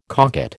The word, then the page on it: cocotte: American English